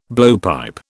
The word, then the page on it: blowpipe: British English